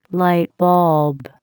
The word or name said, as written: lightbulb